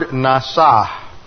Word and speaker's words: nasah: Now the Hebrew word that is translated test is the word nasah. Looks like this in the Hebrew. N-A-S-A-H, and that final H is silent.